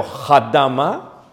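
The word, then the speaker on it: adamá: ¿Cómo se dice eh, tierra en hebreo? Hadama.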